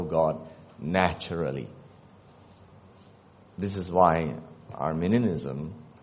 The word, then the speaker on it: Arminianism: It's spelled A-R-M-I-N-I-A-N-I-S-M. If you type A-R-M-E, you'll get a country. Okay? And it's pronounced differently. I know English is very hard to pronounce. Chinese is a bit easier sometimes. But that was Armenia. This is Arminia.